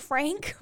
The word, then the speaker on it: Franc: But, can you imagine someone saying, do y'all have any Cabernet Franc? – Slap. No. – I've never actually really thought about that for some reason. It's spelled F-R-A-N-C. It's clearly Franc.